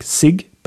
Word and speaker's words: sig: It is written S-I-G, but not pronounced like sig, but sig.